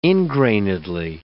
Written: ingrainedly

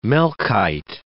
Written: Melchite

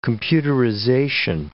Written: computerization

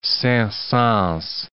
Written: Saint-Saens

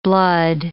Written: blood

